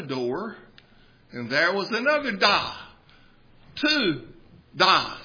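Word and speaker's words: Da (大): That is the Chinese character pronounced Da, which means big or great.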